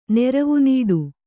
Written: ನೆರವುನೀಡು

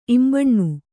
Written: ಇಂಬನಿ

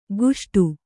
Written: ಗುಷ್ಟು

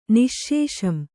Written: ನಿಶ್ಶೇಷಂ

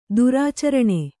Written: ದುರಾಚರಣೆ

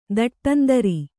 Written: ದಟ್ಟಂದರಿ

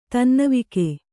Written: ತನ್ನವಿಕೆ

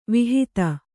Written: ವಿಹಿತ

